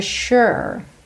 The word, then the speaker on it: sure: Atenção à pronúncia dessa palavra. O S tem esse som sh, de SH, ok? Ou X. E o U não é aquele U fechadinho. É esse som meio ok? Sure.